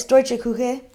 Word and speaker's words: Deutsche Küche: Can you pronounce that? Because I couldn't. – Yeah, it's Deutsche Küche. – Küche? – So it just means German kitchen.